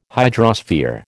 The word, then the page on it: hydrosphere: American English